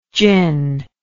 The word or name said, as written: Jin